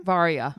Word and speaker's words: Varya: And he already went with Varya, right? – Yes. – How do you say her name? – Varya. – Varya? – Yeah, it's like Aria, but with a V.